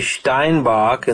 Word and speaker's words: Steinbach: We have Monroe, who's in, I'd like to say, Steinbach in the German pronunciation, just outside of Winnipeg.